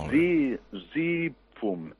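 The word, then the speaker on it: zizyphum: Zi, zi, fum. Zi, zi, fum. Això era més o menys pronunciat així.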